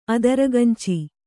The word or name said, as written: ಅದಿರ್ಗಂಚಿ